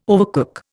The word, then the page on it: overcook: Indian